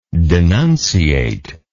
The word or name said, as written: denunciate